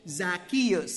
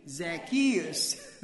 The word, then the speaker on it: Zacchaeus: I was told this morning to make sure that I pronounce the name Zacchaeus in the right way. So am I doing the right way now, church? Zacchaeus. Say that with me. Zacchaeus.